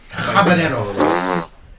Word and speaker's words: habanero: Habanero. It's pronounced habanero.